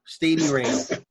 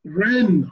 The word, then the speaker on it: Rennes: Stadium Rain. Stadium Rain. Yeah. – This dude is so. – How do you say it? – Rennes.